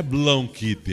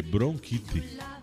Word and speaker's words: bronquite: É bronquite. Não é blonquite, é bronquite. É com R.